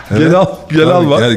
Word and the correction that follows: Celal: Sahibi kim Gelal'ın? Celal. – G yazılıyor, C okunuyor.